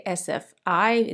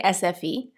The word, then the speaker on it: SFI: I don't even know how to say it because the I in Swedish is pronounced E. So for me, I would say SFI. In Sweden, they say SFE. So I'm going to say SFE.